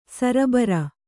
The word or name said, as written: ಸರಬರ